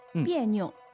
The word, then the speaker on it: 别扭: 嗯，但是在这里呢它是一个多音字，读第四声别，别扭。 – 哎，那么这个扭其实是一个轻声。